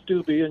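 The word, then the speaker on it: Steube: Congressman Stubbe, and you pronounced it right, he's my own congressman.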